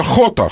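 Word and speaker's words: jota: Кстати, рискну показаться банальным, но вместо «Ж» читается «Х» в этой провинции, я так подозреваю. – Да, да, да. Вот. Это «Хота», «Хота», да, «Хота».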